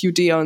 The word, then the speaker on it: Judäa: dass es wirklich Judäa und Samaria heißt.